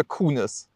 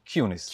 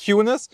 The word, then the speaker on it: QUNIS: Ist Cunis richtig ausgesprochen oder Kunis? Cunis. Cunis.